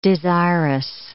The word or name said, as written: desirous